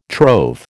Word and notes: trove: American English

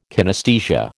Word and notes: kinesthesia: American English

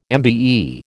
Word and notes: MBE: American English

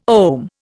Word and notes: ohm: Indian

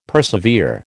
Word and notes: persevere: American English

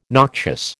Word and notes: noxious: American English

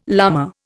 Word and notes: lama: Indian

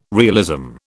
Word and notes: realism: British English

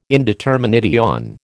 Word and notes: indetermination: American English

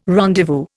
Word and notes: rendezvous: Indian

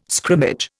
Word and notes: scrimmage: Indian

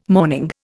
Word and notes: mourning: Indian

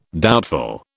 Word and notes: doubtful: British English

